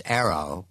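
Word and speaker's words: AARO: All Domain Anomaly Resolution Office, pronounced ARROW, part of the Office of Director of National Intelligence.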